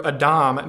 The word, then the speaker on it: Adam: Adam's name, uh, pronounced in the Hebrew Adam, means of the earth.